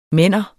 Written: mænner

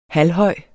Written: halvhøj

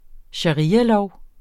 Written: sharialov

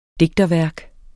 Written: digterværk

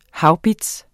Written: haubits

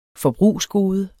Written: forbrugsgode

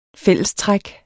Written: fællestræk

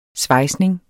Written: svejsning

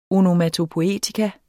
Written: onomatopoietikon

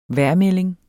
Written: VEJRMELDING

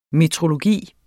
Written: meteorologi

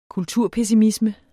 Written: kulturpessimisme

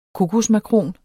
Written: KOKOSMAKRON

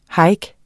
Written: hike

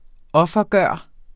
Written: offergøre

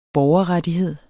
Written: borgerrettighed

